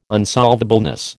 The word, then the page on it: unsolvableness: American English